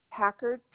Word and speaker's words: Packard: Packard, P as in Paul, A, C, K, A, R, D.